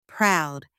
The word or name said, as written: proud